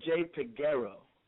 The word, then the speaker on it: Peguero: You're saying it not as bad. I've heard worse, trust me. It's Jay Peguero.